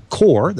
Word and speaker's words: Qor: It's connected to this thing that we call the Core, that's spelled Q-O-R, because it's cute, Core 16 or the Core 32.